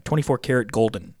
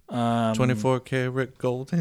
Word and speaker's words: 24kGoldn: This is how you spell it. No spaces. 24KGOLDN, 24 karat golden is what we're going with, right? – Yep. 24 karat golden in the air.